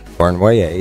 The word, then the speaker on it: Cournoyer: It's signed by Yvan Cournoyer. Did I say that right? – Cournoyer.